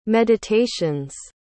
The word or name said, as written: meditations